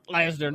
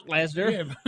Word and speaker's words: Lesnar: It's supposed to be Brock Lesnar, not Brock Lesnar.